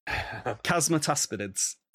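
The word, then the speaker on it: Chasmataspidids: How do you say it? Chasmatespidids. – I'm just going to go with whatever you say. It'll be funny. – No. – Chasmatespidids. – There you go.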